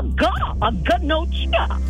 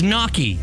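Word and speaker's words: gnocchi: It's a ganochia. – No. – Ganochia. – It's knocky. – What? – It's knocky. The G is silent.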